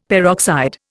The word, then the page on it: peroxide: Indian